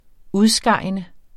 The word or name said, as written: udskejende